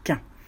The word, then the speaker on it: chien: Mais un petit chien, en Normandie, on prononce « quin ».